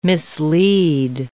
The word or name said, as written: mislead